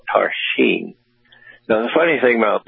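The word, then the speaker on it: Tarxien: So there are a lot of X's when you see Maltese, but they're pronounced like S's. So it's Tarshin, is spelled with T-A-X-X-A-R-E-N-E, Tarshin.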